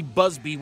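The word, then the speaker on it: Buzbee: This is not going to be the big thing that this Tony Busby, which rhymes with sleazy, thinks it's going to be.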